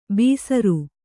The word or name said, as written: ಬೀಸರು